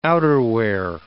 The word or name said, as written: outerwear